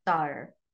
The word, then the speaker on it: Sauer: Matt Sauer or Sawyer or Sur, however you want to pronounce it, spelt S-A-U-E-R. How would you pronounce that? Ready, go. – Sawyer.